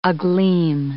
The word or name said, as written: agleam